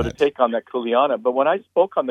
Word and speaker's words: kuleana: The kuleana? Is that how you say it? – Yeah, in Hawaii, it's kuleana is more than responsibility.